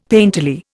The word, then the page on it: painterly: Indian